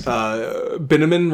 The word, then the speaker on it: Binnaman: But her new man's, Binnaman rhymes with cinnamon.